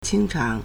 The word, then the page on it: 清偿: qīngcháng